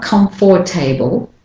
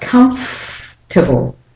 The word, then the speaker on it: comfortable: For example, comfortable, it's not comfortable, but comfortable. The or syllable is dropped completely and you go straight from the F to the T.